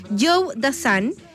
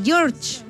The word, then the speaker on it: Joe Dassin: Ya ni me acuerdo, pero es Joe Dassin. Y acá también hay otro auditor que me dicen que se pronuncia George. Pero no, estábamos haciendo las investigaciones y efectivamente es Joe.